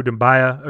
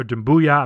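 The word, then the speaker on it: Doumbouya: Again, an A for me for the Siku Dumbuya, or Dumbuya. I mean, it's going to be hard for me to switch up the last name pronunciation, but Dumbuya.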